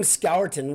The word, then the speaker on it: Scourton: Calling him Scourton, which is the correct grammatical pronunciation, is okay.